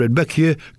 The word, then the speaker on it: Rudbeckia: Now, I think summer bedding and hardy perennial plants, such as Rudbeckia, that's how that's pronounced, Rudbeckia, Coreopsis and Asters, will start dying off this month.